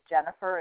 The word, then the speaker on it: Jennifer: My first name is Jennifer. It's spelled J-E-N-N-I-F-E-R, short, S-H-O-R-T.